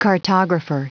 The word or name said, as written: cartographer